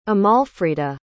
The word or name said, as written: amalfrida